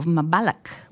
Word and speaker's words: Mbalax: Youssou popularised the popular dance form of Mbalax, which is spelt M-B-A-L-A-X, but pronounced with a K, and its music developed in urban centres in the early 70s.